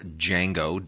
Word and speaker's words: Django: Now, the other thing is you also get a whole bunch of trailers for Django Unchained, or Django, D is silent.